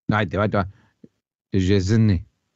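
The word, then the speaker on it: Szczęsny: ¿Cómo se pronuncia Chesney entonces? – Me decían que esa SZ, la primera tiene que ser como shh, y luego era como Chesney. Y acaba como un E, no en Y.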